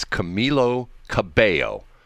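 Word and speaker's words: Camila Cabello: You know, I'll never understand how to pronounce her name because it's Camilo Cabello. So in the first set of L's, you don't pronounce, you do pronounce the L's.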